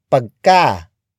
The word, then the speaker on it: pagka: Another way of creating superlative adjectives is by adding the prefix pagka. Pagka. Pagka. Pagka is spelled as P-A-G-K-A.